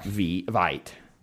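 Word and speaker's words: Veidt: Fun fact, you may pronounce V-E-T, but you're wrong. It has and always will be V-I-T.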